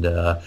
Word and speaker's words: DIIV: some Beach Fossils and Dive. It's spelled D-I-I-V.